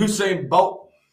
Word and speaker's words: Usain Bolt: You need to turn into Usain Bolt. Catch his ass. That's his name, right? – Yeah, you say it right.